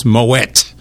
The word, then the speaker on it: Moët: Well, it started out to be Moët Chandon, M-O-E-T, with the E, the O has an umlaut over it, you pronounce the T, so it's Moët and Chandon, and it's actually Moët et Chandon because it's French, therefore the E-T is pronounced E-A. Then they were a wine company,